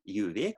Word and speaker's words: Djuvik: My name is Ingeir, Djuvik.